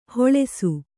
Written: ಹೊಳೆಸು

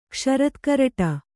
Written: ಕ್ಷರತ್ಕರಟ

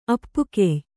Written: ಅಪ್ಪುಕೆಯ್

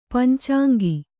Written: ಪಂಚಾಂಗಿ

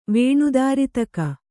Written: ವೇಣುದಾರಿತಕ

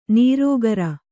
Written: ನಿರಾಕಾರ

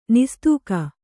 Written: ನಿಸ್ತೂಕ

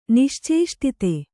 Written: ನಿಶ್ಚೇಷ್ಟಿತ

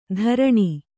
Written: ಧರಣಿ

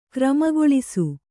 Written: ಕ್ರಮಗೊಳಿಸು

